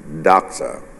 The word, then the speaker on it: doxa: Glory usually in the scripture represents the Hebrew word kabod, and the Greek word, pronounced it, is doxa.